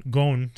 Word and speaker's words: Gon: Gon, sorry, I should say, because that's how they say it.